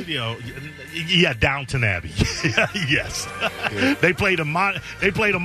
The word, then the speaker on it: Downton Abbey: Downton Abbey.